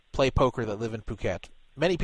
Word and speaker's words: Phuket: It's spelled P-H-U-K-E-T, spelled fuck it. P-H-U-K-E-T, but it's pronounced Phuket.